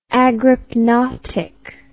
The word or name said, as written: agrypnotic